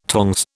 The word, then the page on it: tongs: British English